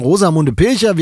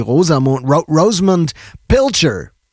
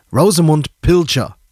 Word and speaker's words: Rosamunde Pilcher: Und im Übrigen, Rosamunde Pilcher wird überhaupt nicht Pilcher ausgesprochen, weil Rosamunde war Engländerin und deshalb heißt sie Rosamund Pilcher. Ne, Pilcher nicht, weil sie kam nicht aus Amerika. Pilcher, probably. Rosamund Pilcher.